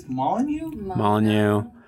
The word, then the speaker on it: Molyneux: he's gone on Stephen Molyneux, is that how you say his name? – I think it's Molyneux.